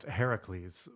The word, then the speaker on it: Heracles: which would be pronounced Heracles, by the way, as you should know from Assassin's Creed Odyssey.